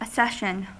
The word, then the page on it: accession: American English